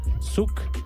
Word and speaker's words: Zuhk: Y entonces estos son Nashi Nashai desde Argentina junto a Zuk, que se escribe Z-U-H-K, Zuk.